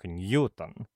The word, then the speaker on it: Ньютон: На английском языке, а Ньютон был англичанином, его фамилия произносится только так – Ньютон. Никаких вариантов, кроме единственно верного, быть не должно.